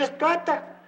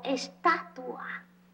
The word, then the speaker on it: estatua: Pues siento así que me quedo tieso como si fuera estoata. – No se dice estoata, se dice menumento. – Pero qué mal están los dos. Ni se dice estuata ni se dice menumento. Se dice monumento o estatua.